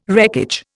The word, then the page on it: wreckage: Indian